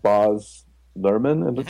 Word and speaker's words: Baz Luhrmann: It was and she invoked the director, I guess, writer of the film, Baz Luhrmann.